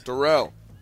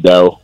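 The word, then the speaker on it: Darell: Darrell. – Or is it Darrell? – Yes, sir. – Oh, sorry, Darrell. – It's pronounced Darrell, but my parents call me Daryl.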